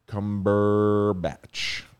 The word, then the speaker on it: Cumberbatch: Figured out how to say it. – Cumberbatch. All right, that's how you say it.